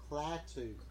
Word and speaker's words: Klaatu: Etu Barada Nicto. I know that shit by heart. Because I ain't going to let that shit get... – You're saying it wrong. – Am I? – It's Klaatu.